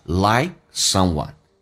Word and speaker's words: like: N 跟 hour 中间可以加个 word 叫 hour， an hour， an hour to like someone， like 的 k 不用发音，气流阻塞一下， like someone，记住 I 稍微的饱满一点， to like someone， an hour to like someone， and a day， and 跟 a 有一个连音，记住 nd 结尾的，把 d 去掉，用前面的 n 进行连音，叫 and a day to love someone。